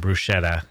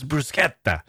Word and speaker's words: bruschetta: Like an American would come to visit and say, "Oh, I'll have some bruschetta," and someone would say, "Ah, it's bruschetta."